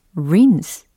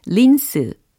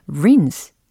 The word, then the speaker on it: rinse: 바로 rinse. 라는 단어거든요. 아, 그래요. 우리가 소위 쉽게 발음하는 린스 있잖아요. 네, 그거를 rinse, rinse 이렇게 발음하시면 되겠습니다. R I N S E, rinse 하고 계시죠?